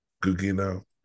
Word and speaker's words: Gugino: Gugino.